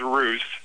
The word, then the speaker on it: Roos: R-O-O-S is ruse, and that's fine. I have R-U-S-S in front of me. And then it says it rhymes with goose.